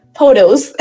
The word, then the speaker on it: POTOS: POTOS.